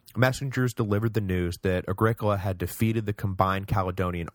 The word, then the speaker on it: Agricola: One possible side consequence of Domitian's mock triumph was that Agricola, and yes, thank you for the pronunciation correction, it is Agricola, not Agricola, which, yeah, I agree, sounds like some kind of terrible hippie soda, so I'm sorry about that.